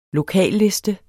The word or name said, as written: lokalliste